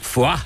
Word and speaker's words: Foix: Genau in Foix, dem heutigen Etappenziel. – Herr Sattelfest, man schreibt das zwar Foix, aber die Franzosen sagen Foix, so wie in Foie Gras.